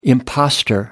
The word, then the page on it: impostor: American English